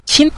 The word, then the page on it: qin: qin5